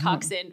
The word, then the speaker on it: coxswain: It's spelled coxswain, but it's pronounced coxswain, which isn't any better.